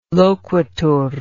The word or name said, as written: loquitur